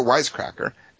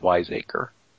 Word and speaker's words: wiseacre: He's quite the wisecracker. Batman's always saying funny lines, so it's a shame. – Well, Dark Knight Rises. – I believe that movie – I believe that word is pronounced wiseacre.